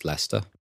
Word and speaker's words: Leicester: It's similar to Leicester, which is spelt Leicester or Leicester, but pronounced Leicester.